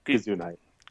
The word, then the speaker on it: Gugino: How do you say her last name? – I'm not sure, but I love her. – Gugino? That sounds wrong. – Gugino.